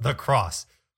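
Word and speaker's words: the X: It's an X, but it's pronounced The Cross for some reason.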